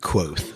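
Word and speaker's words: Kvothe: The audiobook pronounces it quote. So, but, quoth? – Yeah, quoth.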